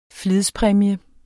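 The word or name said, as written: flidspræmie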